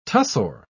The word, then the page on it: tussore: British English